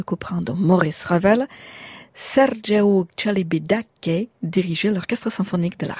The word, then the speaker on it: Celibidache: » Juste, quant à Celibidache, la prononciation qui convient est « Sergeiu Celibidache », donc un tout petit peu à l'italienne.